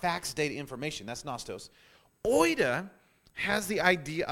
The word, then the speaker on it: oida: The second word for the word knowledge in Greek is the word oida, which is spelled O-I-D-A.